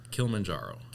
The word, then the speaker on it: Kilimanjaro: Kilimanjaro.